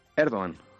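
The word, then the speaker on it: Erdoğan: Veo que pronuncias Erdogan y no como nosotros aquí, que llamamos Erdogan. ¿Los turcos dicen Erdogan o Erdogan? – Erdogan. – Erdogan. – Recep Tayyip Erdogan, sí, sí.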